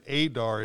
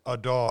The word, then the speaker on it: Adar: And I mispronounced Adar last week. I was informed by the boss lady. It wasn't Adar, it's Adar.